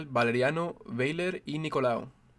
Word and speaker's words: Valeriano Weyler y Nicolau: Capitán General Valeriano Weyler y Nicolau.